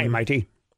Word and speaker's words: Mayte: Mighty, am I saying it right? – Mighty. Hey, yeah, Mighty. How are you? – M-A-Y-T-E.